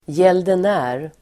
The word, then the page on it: gäldenär: [jelden'ä:r]